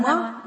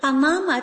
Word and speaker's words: Панама: То есть сама страна Панама, а столица Панама? – Панама. Да, да, одинаковые интересно. Слова, одинаково пишутся, только ударение вот такое разное. Ну, Панама отличается, мне так показалось, она отличается от всей Южной Америки.